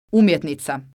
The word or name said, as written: umjetnica